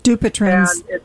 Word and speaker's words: Dupuytren's: So actually, my question is, I've started to develop Dupuytren's, I can never pronounce it properly, contracture in my hand. – Dupuytren's.